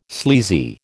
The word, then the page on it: sleazy: American English